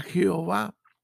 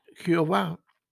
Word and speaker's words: Jehová: La pronunciación Jehová proviene del hecho de que para la lectura de las sinagogas, eh, Yahweh se leía Adonai, el Señor.